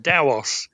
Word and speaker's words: Tao: T-A-O is a Chinese word, and it's pronounced Dao. It's right. Supposed to be an unaspirated T, so it sounds like a D.